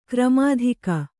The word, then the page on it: ಘರ್ಮೋದಕ: gharmōdaka; kannada